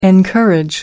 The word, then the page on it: encourage: American English